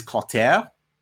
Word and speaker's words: Clotaire: So the name Clothar, which you weren't sure how it's pronounced. – Yeah, because it's spelled C-L-O-T-H-A-R. – I haven't seen Clothar. I don't know. – I read it as Clothar. – In French, it is Clotaire. – I also read it as Clotaire as well. Yeah, it's C-L-O-T-A-I-R-E.